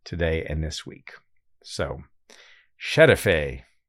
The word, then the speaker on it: Şerefe: Şerefe. – Good enough. Şerefe.